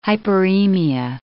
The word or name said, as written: hyperemia